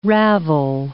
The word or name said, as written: ravel